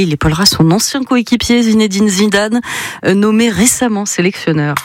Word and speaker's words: Zinedine Zidane: Zinedine Zidane, oui. Pardon, je l'ai un petit peu écorché et on ne peut pas faire ça avec Zizou, c'est pas possible.